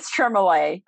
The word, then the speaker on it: Tremoulet: It is Tremoulet. It rhymes with creme brulee.